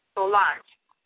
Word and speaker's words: Solange: My name is Professor Solange Martinez, and I'll spell it for you. It's asking Sam, O-L-A-N-G-E, Solange, Martinez, M-A-R-T-I-N-E-Z.